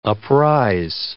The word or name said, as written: uprise